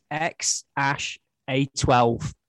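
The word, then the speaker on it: X Æ A-12: Well, is that the correct pronunciation then? – I did. I looked it up. Yeah. So apparently it's ex-Ash-A12. – Okay. – And A12 is apparently Elon's contribution to the name. – So looking at it, it looks like X-A-E-A-X-I-I, which would be a ridiculous name.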